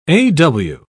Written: aw

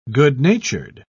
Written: good-natured

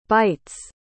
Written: byte's

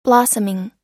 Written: blossoming

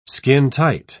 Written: skintight

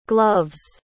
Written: gloves

